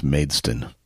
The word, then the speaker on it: Maidstone: So it's pronounced Maidstone. – Yes, Maidstone United.